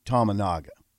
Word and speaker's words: Tominaga: I think you got to continue to include Tominaga. Did I say his name right? – Yeah, he had 11 points, 3 of 11 on threes.